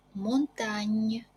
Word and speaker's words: montagne: Буква сочетания ЖН читается как НЬ. Лё пенне, ля монтанне, лё синне, лё манитофонне, ля кампанне.